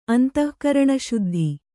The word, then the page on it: ಅಂತಃಕರಣಶುದ್ಧ: antahkaraṇaśuddha